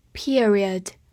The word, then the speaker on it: period: period, p e r i o d, period, p e 读作 peer, r i o d, read, period, period，它是一个名词，表示期间、时期或者是句号。